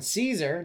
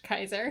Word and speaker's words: Caesar: So, Jesus puts the Ten Commandments underneath his robes so that when Caesar, Julius Caesar, who was the Caesar when Jesus was alive, he wasn't, but that's the joke. – Excuse me, it's pronounced Kaiser.